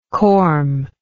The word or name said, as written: corm